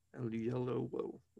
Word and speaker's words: Oyelowo: Oyelowo.